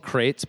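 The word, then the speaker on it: Qrates: There's a company, we don't actually know, we think it's called Crates, but it's spelled Q-Rates, where you can basically say, you know, like...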